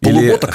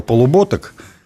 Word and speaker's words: Полуботок: Полуботок? Или, да, значит, Павел Полуботок или полуботок, там по-разному делают ударение, он уже стал наказным гетманом.